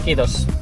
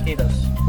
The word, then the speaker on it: Kiitos: How do you say thank you in Finnish? – Kiitos. – Kiitos? – Kiitos. – Like kudos, I suppose.